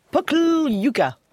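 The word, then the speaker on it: Pokljuka: Si, si, alors ça s'écrit P-O-K-L-J-U-K, donc je dis que c'est Pokl-Yuka.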